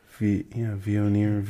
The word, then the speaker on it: Viognier: Viognier. I don't know how you could pronounce that G in there.